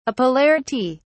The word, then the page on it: apolarity: British English